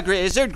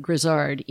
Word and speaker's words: Grizzard: He pronounced it Grizzard, even though everyone else in his family pronounced it Grizzard.